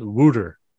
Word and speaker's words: water: Made a trip over there in the mid 2000s and they call it, it's water ice, but they pronounce it wooter.